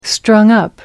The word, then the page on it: strung up: American English